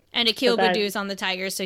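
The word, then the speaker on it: Akil Baddoo: Yes, Akil Badu. I'm pronouncing that correctly. – Yeah, Akil Badu.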